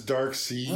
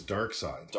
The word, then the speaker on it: Darkseid: I always knew it as Dark Sea. – Right? – But all of a sudden, like, since it's been pronounced, like, in the media, and you hear it pronounced in the media, it usually is Dark Side.